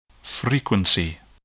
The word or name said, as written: frequency